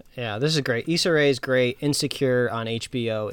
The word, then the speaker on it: Issa Rae: Issa Rae.